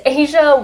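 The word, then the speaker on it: Azsha: Yeah, so it's Asia White.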